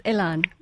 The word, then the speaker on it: Elan: It's Elon. – Elon. Yeah. Elon McAllister. E-L-A-N.